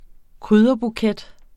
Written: krydderbuket

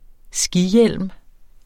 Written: skihjelm

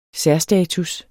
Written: særstatus

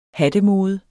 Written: hattemode

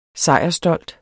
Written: sejrsstolt

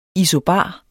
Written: ISOBARER

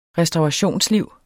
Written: restaurationsliv

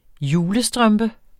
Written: julestrømpe

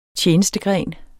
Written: tjenestegren

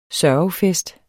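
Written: SØRGEFEST

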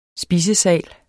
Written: spisesal

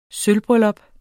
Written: sølvbryllup

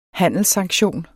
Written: handelssanktion

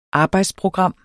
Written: arbejdsprogram

